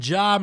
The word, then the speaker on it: Ja: Ja Morant obviously had 25 in the win over the Jazz. It's a soft J.